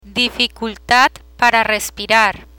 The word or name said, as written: dificultad para respirar